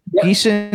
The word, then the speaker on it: Geeson: Judy Geeson. Is that how you pronounce her last name? I'm not sure. Geeson, yeah.